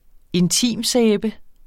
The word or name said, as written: intimsæbe